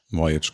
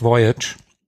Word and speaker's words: Voyage: Ich habe das leider nicht gespielt, aber Wales Voyage. – Voyage, glaube ich. – Ja, ich habe es tatsächlich damals immer französisch irgendwie ausgesprochen, aber natürlich hast du recht, Voyage.